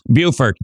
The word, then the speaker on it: Beaufort: Don't say that wrong. They tend to get a little bit touchy about how you pronounce Beaufort, because it's Beaufort in South Carolina.